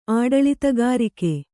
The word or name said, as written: ಆಡಳಿತಗಾರಿಕೆ